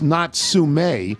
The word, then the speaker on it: Natsume: But we also lay claim to Natsumi Soseki, that's pronounced Natsume, who is probably Japan's most renowned author.